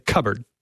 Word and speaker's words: cupboard: So you mean we should pronounce the P in cupboard?